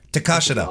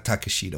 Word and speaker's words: Takeshita: Takashita. – Awesome. Oh, thank Takashita. – You. Takashita. – Not Takeshita. We've been pronouncing it wrong for years. Excalibur's told us how to pronounce it.